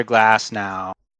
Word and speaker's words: Glasnow: I drafted Tyler Glasnow, or however you pronounce his name. – Yeah, that's right.